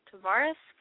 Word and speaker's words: Tavares: Speaking of old people, in Florida, good old Florida, Tavares, Florida? – That's how you say it.